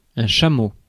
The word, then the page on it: chameau: France; [ʃa.mo]